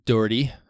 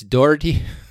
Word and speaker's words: Doherty: Created by Ikey Doherty. I'm going to say it wrong. It's Doherty. – Yeah. There you go. Much better.